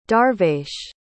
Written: darvesh

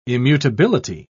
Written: immutability

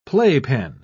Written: playpen

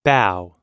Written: bao

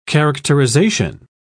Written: characterization

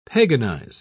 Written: paganize

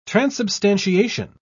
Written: transubstantiation

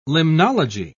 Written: limnology

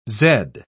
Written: zed

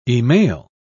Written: e-mail

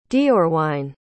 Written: deorwine

